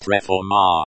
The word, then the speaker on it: reforma: The Russian word for reform is reforma. Pronounced reforma. The word is feminine, so it takes the feminine ending a, like the English word reform.